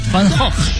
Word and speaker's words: Van Gogh: Van Gogh. 是梵高。 – 发音特别准确。 – Van Gogh.